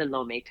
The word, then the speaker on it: Lomé: You were born in Washington, D.C., but you were moved, your family moved and lived in, correct me if I'm saying this wrong, Tomé Logo, is that right? – Yeah, in Lomé, Togo, exactly.